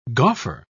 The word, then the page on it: gauffer: British English